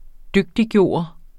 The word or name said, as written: DYGTIGGJORT